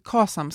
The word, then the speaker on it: KASAM: eh, för att liksom fundera på vilket kas, KASAM ska man tydligen uttala det, har jag då lärt mig.